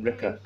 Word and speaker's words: Rika: How do you spell it? – R-I-K-A. – R-I-K-A. – Ricker.